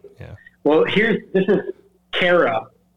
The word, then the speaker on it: Cairo: It's spelled exactly like Cairo. Oh wow! Right, like, but it's Cairo is how they pronounce it, and it's in Illinois.